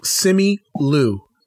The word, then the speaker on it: Simu Liu: Simu Liu. L-I-U. Oh, Simu. Simu Liu. – Spell it for me. – S-I-M-U space L-I-U.